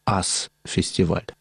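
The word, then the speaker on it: US Festival: В этом месте уже и до этого проводились рок-фестивали, которые имели название «Ас-фестиваль». Пишется как «US-фестиваль». Тут такая игра.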